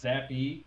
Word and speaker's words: Zappe: Isn't it Zappy? It's pronounced Zappy. – Is there an E at the end? Well, I know there's like an E spelled at the end. Hey, chat, is it Zapp or Zappy? Or Zappy? – No, I'm pretty sure it's Zappy or Zappe. Because they were saying it in the broadcast over and over again. Yeah, Zappy.